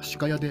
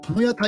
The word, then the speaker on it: 鹿屋: 読み方が、読み方が歯科屋でいいのかな鹿屋体育大学ですね。えー、えー、とですね、鹿児島県の国立鹿屋体育大学に行きました。